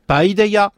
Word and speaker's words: Paideia: Est-ce que je prononce bien Paideia ? – Paideia.